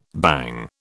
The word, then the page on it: bang: British English